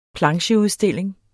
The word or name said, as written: plancheudstilling